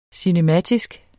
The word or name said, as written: cinematisk